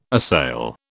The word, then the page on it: assail: British English